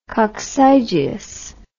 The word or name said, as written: coccygius